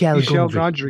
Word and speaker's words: Michel Gondry: Michel Gondry.